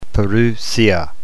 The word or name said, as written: parousia